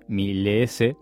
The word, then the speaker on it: Mileece: Se escribe M-I-L-E-E-C-E, Milice,